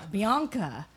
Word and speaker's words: Bianca: Bianca. – The long A.